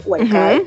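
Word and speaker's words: Huércal: Eso fue, este nombre que me cuesta siempre, Huercal, lo dije bien, Huercal, uh-huh.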